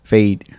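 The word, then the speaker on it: Feid: How you say it? Fade? – Fade. – It's F-E-I-D. Fade.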